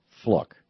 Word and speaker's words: Fluke: It's pronounced like book. Fluke.